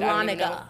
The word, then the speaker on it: Dahlonega: Dahlega? Am I saying that right? – Dahlonega.